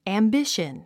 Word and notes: ambition: æmbíʃən; アンビシャン

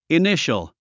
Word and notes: initial: iníʃəl; イニシャル

